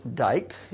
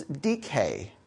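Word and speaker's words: dike (δίκη): Even though it looks like D-I-K-E in English would be pronounced dyke, in Greek it's dike.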